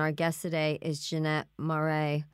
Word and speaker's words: Maré: Jeannette Maré is the founder and executive director of Ben's Bells Project.